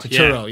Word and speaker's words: Turturro: My favorite character in this movie is, uh, and I'm gonna I always pronounce his name wrong, but John Turturro. Tur- Turturro, Turturro, yeah.